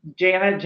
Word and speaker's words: Janet: Well, obviously LinkedIn, Janet, J-A-N-E-T, Zaretsky, it's spelled up there, Zaretsky.